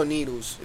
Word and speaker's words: Needles: Is Needless or Needles? – That's what I want to know. – Because I've heard people say it both ways. – It's spelled needles. I'm gonna pronounce it needles.